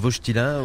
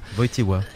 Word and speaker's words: Wojtyła: Wojtyła, c'est la prononciation juste. – Nous, on disait plutôt Wojtyla. – Mais la vraie prononciation, c'est Karol Wojtyła. – Wojtyła.